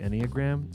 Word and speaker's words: Enneagram: We were talking about the Enneagram. Is that how you say it, right? – Yeah, it is.